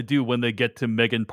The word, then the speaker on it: M3GAN: She never actually pronounces the three in her name, Jeff. Mm-hmm. Yeah. – You mean M3GAN? Doesn't? – M3GAN.